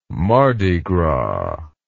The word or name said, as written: mardi gras